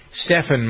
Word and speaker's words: Stephan: Stephan, my name, is spelled S-T-E-P-H-A-N. So Curry's name is first name is spelled S-T-E-P-H-E-N, yet they call him Steph.